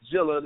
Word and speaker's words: Gilla: Instagram is Juice Jilla. That's in one word, JuiceGilla. The G is pronounced with the J.